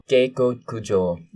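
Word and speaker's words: Gekoku-jo (下剋上): There's a supposed to be like a line over the O at the end. – Gekoku-jo. – There you go.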